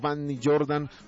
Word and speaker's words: Vanny Jordan: Bueno, marcando mi nombre, Vanny Jordan, ¿cómo se escribe? – Se escribe B pequeña, o sea, V, B de vaca, A-N-N-Y, y después espacio Jordan, J-O-R-D-A-N, Vanny Jordan.